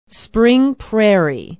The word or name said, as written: spring prairie